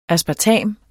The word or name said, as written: aspartam